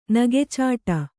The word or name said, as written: ನಗೆಚಾಟ